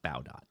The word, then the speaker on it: Baudot: Emile Baudot or Baudot, or, but it's pronounced Baudot.